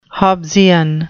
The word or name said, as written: Hobbesian